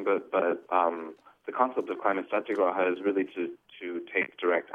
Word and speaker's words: Satyagraha: Is that how you say it? – Yes, Climate Satyagraha.